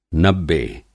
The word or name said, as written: नब्बे